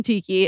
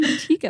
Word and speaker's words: tikka: Am I saying that right? Chicken tikka masala? Tikka masala? I think it's tikka. – Yeah. – I always say chicken tiki and I know that's wrong every single time.